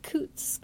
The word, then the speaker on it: Coutts: It was a Coutts Bank, is that how you pronounce it? Mm. Check? – Yeah.